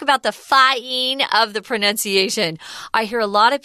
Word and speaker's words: pronunciation: But unfortunately, like some English words, we make it harder and more tricky for you because we change the pronunciation when it turns into a different form of the word. This is the noun form, and it's pronunciation. The verb is pronounce. Pronounce, but pronunciation, be careful with that.